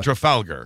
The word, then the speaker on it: Trafalgar: Well, he talks about Trafalgar, and I don't know if that's how you pronounce it, but Trafalgar. – Trafalgar, yeah, there you go.